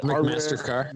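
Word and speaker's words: McMaster-Carr: Dude, if you just go through your Amazon and your McCarster, how do you say that? That McMaster car. – McMaster car.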